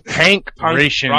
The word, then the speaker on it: Pankration: It's Pankration. – Pankration. – They say it's Pankration. – If you go on the pronunciation they're giving you, it's Pankration.